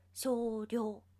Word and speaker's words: 精霊: 今日は少量の日と言われています。漢字で書くと、精霊という字になるんですが、読み方としては少量なんだって。